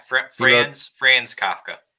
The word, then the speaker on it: Kafka: Yeah, Franz Kafka.